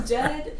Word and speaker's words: Dehd: Dead, it's spelled D-E-H-D, and they're from Chicago.